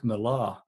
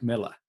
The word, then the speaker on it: Millar: But this is the thing, but that's if you look at the spelling of Mark Miller, it looks like Mark Millar, which is how a lot of Americans say it, but it is Mark Miller.